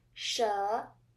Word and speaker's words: 蛇: The first Chinese word with the second sound is "shé." It means snake in English.